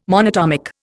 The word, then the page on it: monatomic: Indian